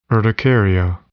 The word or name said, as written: urticaria